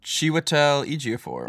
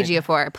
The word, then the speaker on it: Ejiofor: Ejiofor, right? – Ejiofor plays in the movie.